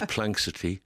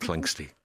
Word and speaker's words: Planxty: People couldn't pronounce it. Some people would say Planxty. There was Planxity, Playtex, Plantex. It took a year or so before people got the head around Planxty.